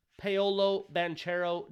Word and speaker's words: Paolo Banchero: Paolo Banchero, do his damn thing.